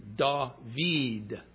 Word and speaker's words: David: However, the dot that's under the middle letter, the vav, that dot, because it's a single dot, does have an effect on the pronunciation. It makes it an E sound, just like R-E, our alphabet's E. So it's David.